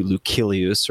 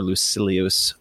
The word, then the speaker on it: Lucilius: It's the Moral Letters to Lucilius, or Lucilius, depending on how you want to pronounce it.